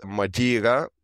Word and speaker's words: Madeira: But there is one place which I like more every time I go, and that is Madeira. This is the English pronunciation, or Madeira Island.